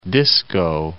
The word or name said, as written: Disko